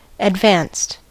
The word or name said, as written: advanced